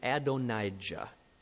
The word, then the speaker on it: Adonijah: And it's about one of his other sons that I want to build this sermon around today, a man named Adonijah. That is spelled A-D-O-N. That's the Adon, A-D-O-N, and then I-J-A-H, I-J-A-H. Adonijah.